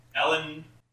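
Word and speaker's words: Ellin: Wait, his name is Alien La? – I probably pronounced that incorrectly. It's E-L-L-I-N. Ellen.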